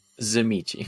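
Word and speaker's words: Tzimisce: Zimichi. – Zimichi. – And it's spelled like Tizimisk, so it doesn't help.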